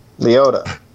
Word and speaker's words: Liotta: Liotta.